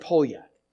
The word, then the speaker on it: Poljak: It looks like P-O-L-J-A-K, right? But it's pronounced Polyak.